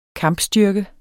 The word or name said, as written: kampstyrke